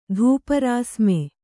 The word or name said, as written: ಧೂಪರಾಸ್ಮೆ